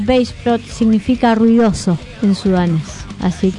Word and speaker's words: Baceprot: Voice of Bassprot, pronunciado sería Bassprot, a menudo abreviado BOB, es un trío de rock femenino de Indonesia formado en Garut, Java Occidental, en el 2014.